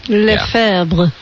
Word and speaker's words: Lefebvre: In 1905, Archbishop Marcel Lefebvre was born this day. – You did a good job pronouncing that.